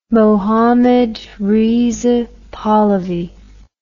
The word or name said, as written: Mohammed Reza Pahlavi